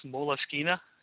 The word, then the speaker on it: Moleskine: Apparently it's pronounced Moleskine. Is that right? – Yeah, it's a weird name.